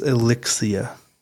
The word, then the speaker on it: Elixcia: At seven fifteen p.m., as he drove, he called his wife Alexia when she picked up? – That's Elixcia. You have an interesting accent that makes it sound like Alexia, but it's Elixcia.